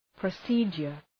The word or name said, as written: procedure